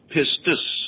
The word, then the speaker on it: pistis: Now, after the second P-I-S-T, I want you to put I-S, which in the Greek is pronounced pistis, P-I-S-T-I-S. So we have 2 words out of that stem, P-I-S-T-O-S, which means faithful or faithful one, pistis, which means faith.